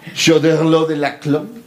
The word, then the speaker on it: Choderlos de Laclos: Es Choderlos de la Clos.